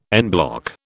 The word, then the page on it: en bloc: British English